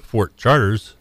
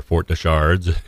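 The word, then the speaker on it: Fort de Chartres: And of course, just moving down the river a little bit here, we've got Fort Charters, or Fort de Chards, if you pronounce it correctly, another site here in Randolph County that has been in the news here.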